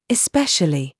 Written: SHOE

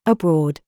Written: THOUGHT

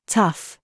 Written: STRUT

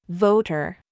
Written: VERVE

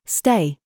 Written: THIS

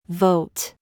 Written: TIGHT

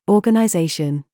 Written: THOUGHT

